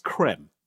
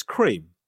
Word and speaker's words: Kreme: Was Krispy Kreme originally meant to be pronounced creme? I said. – Yeah. – Hey. Hope you're well. We've always been pronounced as cream.